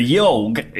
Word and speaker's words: YAHWG: The Yorg is a very strange game. It is. – It looks like a typo. – It does look like a typo. It's spelled YAHWG, and essentially, it's a social choose-your-own-adventure game.